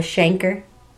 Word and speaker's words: chancre: So is it like a canker? It's, only it's called a chancre. – It rhymes. – Yeah, it does rhyme.